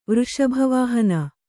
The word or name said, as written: ವೃಷಭವಾಹಿನಿ